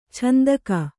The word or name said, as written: ಛಂದಕ